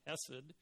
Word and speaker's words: hesed: In fact, in Hebrew, the word loving kindness is a translation of a Hebrew word that's pronounced hesed.